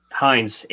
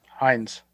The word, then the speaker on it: Hinds: Number three, Sirian Hinds. I can't. I'm sure I butcher his name. – If you don't know, then we is it Hinds or Hines? – Hines, H-I-N-D-S. – Hines. – Yeah, and then first name is C-I-A-R-A-N.